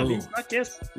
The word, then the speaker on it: vi snakkes: Yeah, vi snakkes.